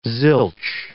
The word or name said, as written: zilch